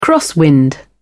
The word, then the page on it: crosswind: British English